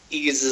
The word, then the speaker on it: Ease: only let him join if he knows how Ease is pronounced. Is it Wise? He says, is it Wise or is it like cheese? And I was like, it's like cheese, and he gave me the thumbs up.